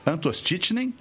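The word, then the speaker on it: Antoszczyszen: Parabéns por ter pronunciado meu sobrenome, isso é uma raridade. – Pô, quase acertando já, né, professor? – Quase acertando. Eu vou soletrar só por curiosidade que estamos escutando. – Legal, por favor. – É A-N-T-O-S-Z-C-Z-Y-S-Z-E-N. Então, o caro colega está de parabéns pela pronúncia, bem complexa. – Muito legal. – Obrigado pelo convite. – Z-C-Z, é muito raro assim de contar.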